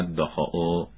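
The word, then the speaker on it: Dachau: تذکر تلفظ کلمه های آشویت و داخاو صحیح می باشند.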